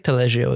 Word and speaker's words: Taleggio: Herb roasted chicken, pork, and Taleggio cheese. – Taleggio. – I'm probably saying that wrong. – No, I feel like Taleggio is right.